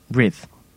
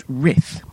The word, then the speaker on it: rith: And there was one word that was a real kind of key one that was written R-I-T-H. So the tester was expecting it to be pronounced writh, like it rhymes with with. Which is kind of stupid, because English has so many different ways of pronouncing the similar word conjunctions that you really shouldn't judge someone's dyslexia based on that alone. So I pronounced it writh, as if it rhymed with the word Sith.